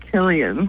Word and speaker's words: Cillian: I just found out actually that his name is pronounced Killian, which you wouldn't expect by looking at it, but it's like the Boston.